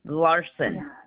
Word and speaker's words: Larson: Debbie Larson.